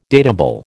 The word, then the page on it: datable: American English